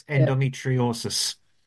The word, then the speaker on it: Endometriosis: It's Endometriosis Association of Ireland.